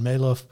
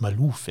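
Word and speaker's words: Maalouf: Marvin Maloff. Ja, für den äh, lief es in der ganzen Saison ja nicht ganz so gut. Malouf wird er, glaube ich, ausgesprochen.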